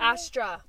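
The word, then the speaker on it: Astraphobia: But, astrophobia. – Astrophobia? – Astra. Let me spell it for you. A-S-T-R-A phobia. Astra. A-S-T-R-A. – Oh.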